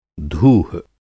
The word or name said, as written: धूह